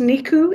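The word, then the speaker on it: Nicu: And this is from, I think it's pronounced Niku. it's N I C U.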